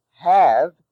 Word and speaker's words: have: R, A-R-E, rhymes with car. Have, H-A-V-E, although it is in the cave-save spelling family, is pronounced have. There is no word in English pronounced have. However, as a syllable, H-A-V-E is pronounced have in the word behave.